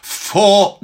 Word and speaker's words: vor: Vor dir. And again, make sure the V is pronounced as an F there.